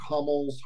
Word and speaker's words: Hummel: And the podcast, if anyone's interested in that, that's called Mark Hummel's Harmonica Party.